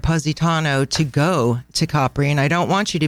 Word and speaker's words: Capri: And just so all of you get the pronunciation correct, it's Capri, not Capri.